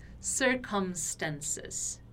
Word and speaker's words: circumstances: Então, quando eu tenho a letra M no meio da palavra, como é o caso aqui de circumstances, eu tenho que pronunciar esse M como realmente um M, ou seja, fechando os lábios. Circumstances, ok? Circumstances, circumstances. Circumstances, tá? Então não é circumstances, porque não é um N, é um M.